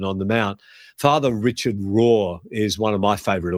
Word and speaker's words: Rohr: Richard Rohr, his surname is pronounced is spelled R-O-H-R.